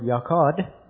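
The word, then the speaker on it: yachad: It happens to be the Hebrew word yakad. If you want to spell it, it's Y-A-C-H-A-D.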